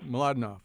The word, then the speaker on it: Mlodinow: Did I just say that right? Mlodinow? – Mladenov. – Okay, I didn't do a very good job at all.